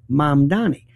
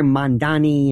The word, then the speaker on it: Mamdani: It's Mom, like Mom and Dad, Donnie, like Donnie Osmond, Mom Donnie. But you hear Mandani and Mandani.